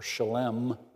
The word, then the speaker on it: Salem: Now, Salem, or that's the way we read it here, but it's actually pronounced Shalom, or Shalem, means peace.